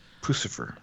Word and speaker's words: Puscifer: How do you pronounce it? – Pucifer. – Okay, Pucifer. – P-U-S-C-I-F-E-R.